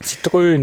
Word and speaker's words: Citroën: Jedenfalls hat Citroën bekannt gegeben, dass sie jetzt wirklich auch in Deutschland auch Citroën nennen, so wie es auch viele Deutsche aussprechen. Und sie haben es dann wirklich Z-I-T-R-Ö-N geschrieben.